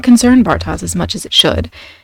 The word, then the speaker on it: Bartosz: Now, the character whose name I can't pronounce, I believe it's pronounced something like Bartosz, but I'm struggling. So we're going with Bartosz?